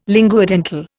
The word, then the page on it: linguodental: Indian